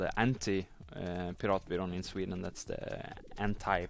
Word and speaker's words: Piratbyrån: So apart from the Pirate Bay system, there's also a group called Piratbyrån. Did I pronounce this correctly? – Quite nice. Piratbyrån. Yep.